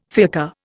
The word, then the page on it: fiacre: Indian